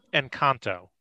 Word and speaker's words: Encanto: You're calling it Encanto? – Yeah. – I believe it's Encanto. And I'm sure I'm butchering it, but there's no H after the C, sir.